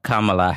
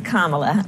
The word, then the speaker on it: Kamala: Joe Biden's pick of Kamala Harris as his running mate reminded me of that brief encounter.